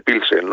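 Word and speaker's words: Plzeň: Y bueno, el Vitória se escribe Plens y se dice Pilsen, ¿no?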